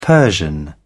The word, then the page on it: persian: British English